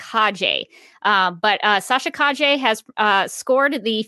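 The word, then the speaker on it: Calle: and I believe her name is pronounced Kaje. I looked up a video. I looked up a video of her, and this will be in the video description below. And I listened to her say her own name, and to me, it sounded like Kaje.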